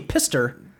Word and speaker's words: Pfister: It's like Price Pfister. Pfister. Well, I guess either way. Well, it would be Pister. – Right. – Well, you got the F in there. – The P is silent.